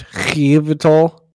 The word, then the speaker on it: Yveltal: It's Yveltal.